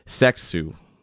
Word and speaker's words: Seksu: Here's Thursday by Asobi Seksu. That's S-E-K-S-U.